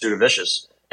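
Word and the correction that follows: Jasudavicius: It's Jazz DeVicious.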